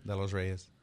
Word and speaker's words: De Los Reyes: De Los Reyes. – De Los Reyes. Uh-huh. You don't roll your R, though.